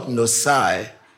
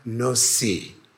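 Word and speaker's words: Nissi: It's not Nosai, it's Jehovah Nosi.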